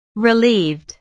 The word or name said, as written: relieved